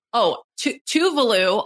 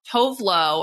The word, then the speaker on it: Tuvalu: But Tuvalu, also, by the way, I have been saying that wrong. Tovlo, or whatever, is how I was saying it.